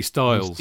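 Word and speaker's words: Stiles: they would know Nobby Styles. – Steelers. – They would pronounce it Nobby Steelers.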